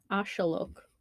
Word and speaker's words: axolotl: It looks like a pregnant axolotl. – It does. It's a very accurate description. – Or ashalok. Because apparently that's how it's pronounced in Mexico.